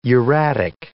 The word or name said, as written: uratic